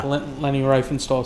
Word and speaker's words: Riefenstahl: Leni Riefenstahl certainly would fit into that. Again, I'm sure I'm mispronouncing that. Sorry. – That's pretty good.